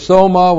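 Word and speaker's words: Soma (σῶμα): S long O-M-A. Soma is the Greek word for body.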